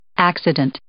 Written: accident

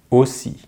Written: aussi

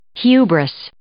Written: hubris

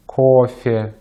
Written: кофе

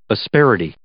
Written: asperity

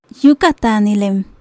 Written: Yúukataanilen

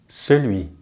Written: celui

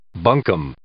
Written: bunkum

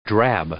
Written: drab

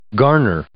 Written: garner

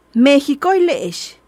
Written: Meejicoile'ex

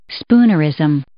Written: spoonerism

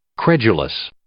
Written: credulous

